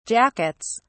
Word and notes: jackets: British English

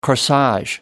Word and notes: corsage: American English

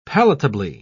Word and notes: palatably: British English

